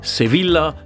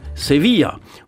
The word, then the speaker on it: Sevilla: Also nicht Mallorca, sondern Mallorca, nicht Bobadilla, sondern Bobadilla und nicht Sevilla, sondern Sevilla.